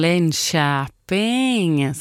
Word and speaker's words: Linköping: Och han är från Linköping, som han skriver själv. Med korrekt då fonetiskt uttal på Linköping.